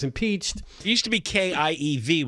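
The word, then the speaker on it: Kyiv: They've also bombed a shopping mall in Kiev, which, by the way, over the weekend I learned, John, why it's pronounced Kiev. Kiev was the Russian pronunciation. The Ukrainian language, it's a little different than it comes out more Kiev.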